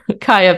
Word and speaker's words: Kiah: So Kaya is spelled Kiah. You can also always find me on LinkedIn, the most boring social media. I probably shouldn't say that in case LinkedIn gets mad, but Kaya Penfield, KiahPenfield.